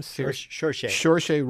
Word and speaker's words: Saoirse: Saoirse Ronan, who starred in Lady Bird, and of course, the perennial favorite, Meryl Streep, who played Kay Graham in The Post.